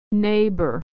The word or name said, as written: neighbor